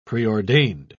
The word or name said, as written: preordained